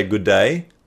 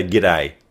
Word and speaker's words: g'day: The British say good day. We say g'day.